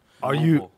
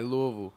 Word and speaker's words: Louisville: Louisville. – We say it like Louisville. – Louisville. – Everybody say Louisville and all like that. But nah, when you from Louisville, though, you know it's Louisville, you know? – Do you know who else is from Kentucky?